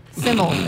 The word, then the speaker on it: SYML: Simmel. It's pronounced Simmel.